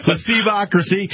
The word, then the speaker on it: Placebocracy: We're talking with Mark Hartwig, the book Placebocracy. Did I say it right? – You got it. – Yeah. – Placebocracy and Other Ailments, a classic liberal take on America today.